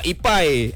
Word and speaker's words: Epy: Sebab ejaannya D-Y-A-N-A. – Oh, macam saya ni lah, Ipai. E-P-Y, Ipai, Syafiq. – Maknanya awak kena tukar E-P-I. – E-P-I lah, eh.